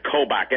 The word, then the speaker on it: Kobach: Well, first of all, let's place this guy, Kobach, and it's spelled K-O-B-A-C-H. Sounds like Coke with a B-A in the middle.